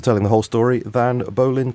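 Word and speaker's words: Bollyn: And the other one was Solving 9/11, The Deception That Changed the World by Christopher Bolin. Is that right? – I think Bolin is how he pronounces it.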